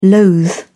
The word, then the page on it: loathe: British English